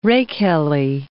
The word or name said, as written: rakehelly